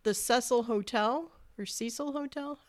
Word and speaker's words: Cecil Hotel: But John Berlinger, he helmed the Cecil Hotel. How do you pronounce that? – Mm-hmm. The Cecil Hotel.